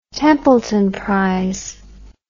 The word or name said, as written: Templeton Prize